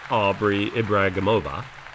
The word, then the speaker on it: Ibragimova: Aubri Ibragimova. – Perfect. – I know. That's my Russian. – You pronounced that perfectly.